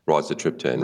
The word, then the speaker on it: rizatriptan: Rizatriptan.